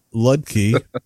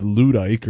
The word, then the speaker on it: Ludke: Michael Ludke, who I pronounced Ludike or something like that, and was corrected, said, that Jersey accent, Yoda, the sound kind of sounds like an R.